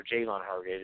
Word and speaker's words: Javon Hargrave: So Javon Hargrave, or Javon Hargrave is how it's actually pronounced, he's playing well.